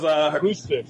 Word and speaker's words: Rusev: Yeah, because now that fucking Rusev, Rusev, I can't say his name today for some reason, because Rusev. – Thank you, because he's MIA.